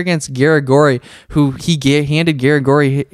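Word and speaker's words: Garagorri: And then Darren Elkins revived his career against Edwardo Garigori, right? Is that how you say his name? Yeah, Edwardo Garigori.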